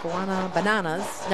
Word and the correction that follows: Guanabanas: No. Guanabanas. That's right.